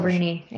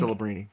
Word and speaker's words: Celebrini: So LeBronny, you know, Sabrani or whatever you want to, whatever his pronunciation, Celebrini, I think. – Celebrini.